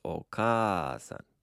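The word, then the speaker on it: okasan (お母さん): Looks like okasan. – Yeah. And notice that there's a long a in the middle of that word. So it's okasan.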